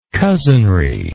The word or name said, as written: cousinry